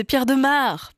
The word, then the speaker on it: Pierre de Maere: Ça aurait rimé. Mais non, en fait, c'est Pierre de Maere, parce qu'il est belge. Prononce pas pareil que si c'était un umlaut, par exemple.